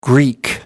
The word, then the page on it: Greek: American English